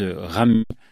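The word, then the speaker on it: Ramuz: C'est l'auteur suisse Ram. Avec un Z à la fin, mais les Suisses ne prononcent pas le Z, ouais.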